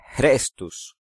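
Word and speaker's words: Chrestus: Suetonio opinaba que la orden estaba relacionada con un hombre llamado en latín Crestus. Su pronunciación aproximada es Crestus.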